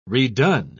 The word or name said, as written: redone